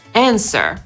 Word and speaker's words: answer: Po amerykańsku wymawiamy jako answer.